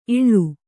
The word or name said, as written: ಇಳಿ